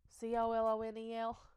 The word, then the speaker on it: colonel: After word got out, there were many rumors and speculations as to what happened, and a horror film in which Colonel Colonel C O L O N E L Colonel. I hate that word. – I know you just spell, talked about that at work. Spell it like the popcorn kernel, please, because people like me just don't understand.